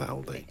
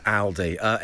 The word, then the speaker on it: Aldi: But I think we should clarify that it is Aldi. It is pronounced Aldi. – It is Aldi.